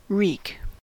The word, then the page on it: wreak: /ˈɹiːk/